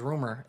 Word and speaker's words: RMR: Well, his name is Rumor. That's how you... – Rumor. That's how you say it, right? – Yeah.